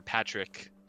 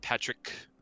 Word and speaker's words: Patrick: So like my name, Pat or Patrick, it would be pronounced Patrick, you know, with like a little bit of an oo at the end of it.